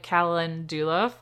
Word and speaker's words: calendula: We also got dried calendula flowers.